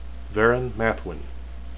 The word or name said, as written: verin